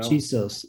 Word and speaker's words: Chisos: Chisos Mountains. – Oh yeah, Chisos, you know. – Chisos. Oh yeah, that's probably the correct Chisos Mountains.